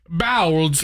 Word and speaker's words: Bowlds: Bowlds. – It's B-O-W-L-D-S. – It's pronounced like the movement with a D at the end.